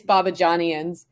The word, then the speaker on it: Babajanians: It's pronounced phonetically, so it's Babajanians.